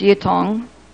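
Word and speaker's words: Ytong: Number two, building materials, cement, chalkstone, earthstone, lumber, metals, sand and gravel, gypsum, aluminum, pumice, glass, whitong, and kiln dust. Number three. – Whitong is pronounced yitong.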